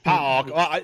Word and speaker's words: PAOK: PAOK, I think. – PAOK. An actual Greek person pronounced it right. PAOK to me, so.